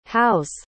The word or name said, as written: how's